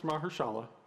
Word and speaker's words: Mahershala: No, it's Mahershala. Yeah. – Okay, Mahershala. Okay.